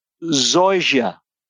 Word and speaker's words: Zoysia: Zoysia.